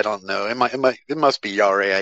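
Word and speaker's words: Jarre: Is it Yare, the score's composer? – Yes, I don't know if I've ever really said it out loud.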